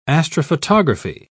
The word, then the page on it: astrophotography: British English